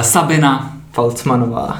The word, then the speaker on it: Falcmanová: uh, Sabina. Falcmanová. Přesně tak. Vyslovuju to správně.